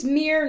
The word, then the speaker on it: Mir: So, hi, my name's Mir now. I'm non-binary. That's spelled M-I-R, like the Russian space probe.